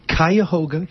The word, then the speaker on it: Cuyahoga: Cuyahoga Community College.